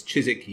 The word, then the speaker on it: Chiswick: Chiswick yeast. – There's a W in there. – Well, there is in the spelling, but not in the pronunciation.